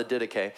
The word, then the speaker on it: Didache: It's spelled like Didache, which I think is a cooler way to say it, but the Didache.